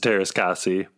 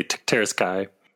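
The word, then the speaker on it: Teras Käsi: Maybe you had more fun with it because you pronounced it wrong. – It's hard for me to say it every time. I just want to say Teras Kai.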